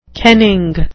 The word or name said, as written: kenning